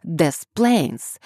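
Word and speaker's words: Des Plaines: Говорят «Des Plains», произнося непроизносимые «S».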